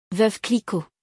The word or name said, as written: Veuve Clicquot